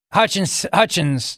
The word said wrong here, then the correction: Hutchinson: did I say that correctly? – Hutchinson.